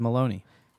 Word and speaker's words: Maloney: Or you might know him as Yves Maloney. – This rhymes with baloney.